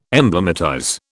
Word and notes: emblematize: British English

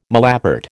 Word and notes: malapert: American English

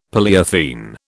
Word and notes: polyethene: British English

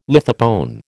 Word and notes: lithopone: American English

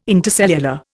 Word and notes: intercellular: Indian

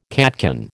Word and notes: catkin: American English

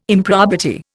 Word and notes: improbity: Indian

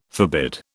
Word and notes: forbid: British English

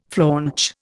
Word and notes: flaunch: Indian

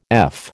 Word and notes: F: American English

